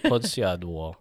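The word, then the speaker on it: Podsiadło: David Podsiadwo.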